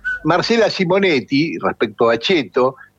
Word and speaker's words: ceto: En italiano, según el lufardismo, donde la palabra ceto, que se pronuncia cheto, significa clase social.